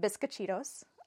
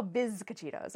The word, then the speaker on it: bizcochitos: Our last episode, we did the remake of The Hills Have Eyes, and I made bizcochitos.